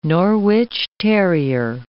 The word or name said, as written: Norwich terrier